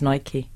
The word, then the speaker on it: Nike: I think young people say Nike. – No, it's not that. Americans say Nike. And it's an American brand, so it's Nike.